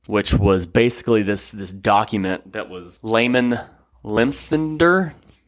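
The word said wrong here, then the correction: Lemnitzer: General Lyman Limnitzer.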